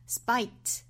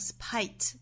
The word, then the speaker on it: spite: 我们首先来看 in spite 这个 spite 这个词，那我们注意 s 后面的这个清辅音呢，我们说是不送气发音哈，所以我们应该是 spite 不要发成 spite 啊 spite。那还要注意这里面的元音应该是一个 i 的发音，是 spite 不要发成 spite，应该是 i。